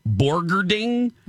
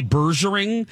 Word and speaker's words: Borgerding: One of them was Faith Borgerding. Oh. And I kept wanting to say Bergering. And so I had to phonetically write it out, and I was so worried. Yep. Borgerding. Faith Borgerding.